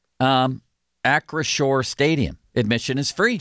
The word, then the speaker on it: Acrisure: August 29th through September 2nd at Acrisure Stadium. Huh. There's a little pronunciation here that says Acrisure. I think I've been calling it Acrisure.